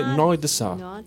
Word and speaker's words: Noir Désir: Se escribe Noir Désir, pero en francés se dice Noir de Sa.